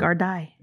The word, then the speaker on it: Gardaí: Is it the Gardaí or is it the Gardaí? – I looked it up. Gardaí. – It's pronounced that? – Like that? How do you spell it again? – It's G-A-R-D-A-I with a little. – It's Gardaí. Okay.